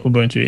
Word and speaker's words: Ubuntu: I think I am interested in this idea of, how do you pronounce it? Ubuntu? – Yeah, Ubuntu, yeah.